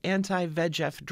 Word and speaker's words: anti-VEGF: That's the word, the prefix anti, followed by the capital letters VEGF. I pronounce it anti-VEGF drugs.